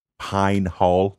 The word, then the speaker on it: Pine Hall: I was born in, I'm from a really, really small rural town called Pine Hall, North Carolina. I have to overpronounce it like that because otherwise my accent would make it sound insane. But I grew up in a town called Pine Hall.